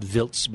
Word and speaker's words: Wiltz: It's pronounced Vilts by the, the, the locals.